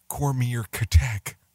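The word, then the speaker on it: Comirnaty: Though the review process ahead of granting EUA for Pfizer vaccine, which will now be marketed as a commonary pronounced Cormier Katek. Yeah, good luck with that.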